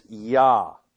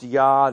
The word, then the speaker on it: Yah: We would write it like this in English. Jah. In Hebrew, it is Yah. Pronounced Yah, this name signifies, it means simply He is.